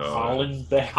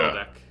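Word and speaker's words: Houellebecq: Wait, is that how you say his name? – Welbeck? – Yeah. – That's how you say it? – Yeah, how did you say it? – I was saying, like, no. Hollenbeck. – It's just Welbeck.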